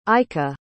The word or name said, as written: ica